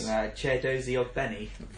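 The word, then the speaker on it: Chiedozie Ogbene: Chedozie Ogbeni.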